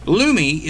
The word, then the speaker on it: Lume: Lumi, L-U-M-E, it's pronounced Lumi. Lumi is a deodorant made specifically for things down there.